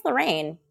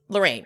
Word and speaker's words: Loryn: She wants to let go of feeling like she could have done something for Lauren? Now I just don't know how to say her name. – I think it's Lorraine. – Lorraine, right? It's just spelled Loryn.